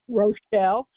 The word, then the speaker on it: Rochelle: S-A-U-G-E-R-T-I-E-S, yeah. – New York, 12477. – And what's your name? – Rochelle, R-O-C-H-E-L-L-E, Dabrowski, capital D. E, capital B as in boy, R-O-S-K-Y.